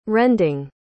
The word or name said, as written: rending